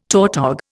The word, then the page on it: tautog: Indian